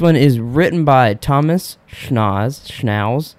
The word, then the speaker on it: Schnauz: Do you know how to say his name? It's S-C-H-N-A-U-Z. – I think it's Schnauz. – Schnauz? – It's not Schnauz. It's... – Because then the next... – You were right the first time.